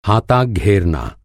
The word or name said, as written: हा